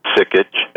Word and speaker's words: Sikich: My name is Gary Sikich. First name is spelled G-E-A-R-Y. Last name, S like Sam, I-K-I-C-H.